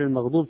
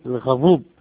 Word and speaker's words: المغضوب: غير المغضوب تنطقها غير الغضوب، الغضوب يعني الميم ما في والضاد صار الضاء، ومثل الذين تنطقها الذين بالدال، فهذه الصلاة صحيحة؟